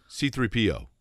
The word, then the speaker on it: C-3PO: Have I been saying it incorrectly all this time? I say, um, C-3PO. No, wait a minute. – C3PO. Yeah, you said C3PO. Like you, you, dragged the PO right there.